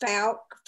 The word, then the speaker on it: Fouke: For years before, there had been sightings uh, it's actually pronounced Fouke.